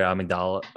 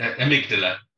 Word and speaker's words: amygdala: But then, like you said, it's going into your amygdala, or how do you pronounce it? Is it amygdala? – Amygdala. I was reading the book and I kept on pronouncing it amygdala for some reason.